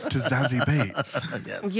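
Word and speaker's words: Beetz: And it's spelt Beats, but it's pronounced Bates.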